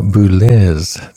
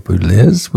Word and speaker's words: Boulez: Pierre Boulez, sorry, I should pronounce his name correctly. Pierre Boulez, the non-intuitive pronunciation of his name, Pierre Boulez, with the Cleveland Orchestra.